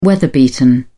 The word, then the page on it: weather-beaten: British English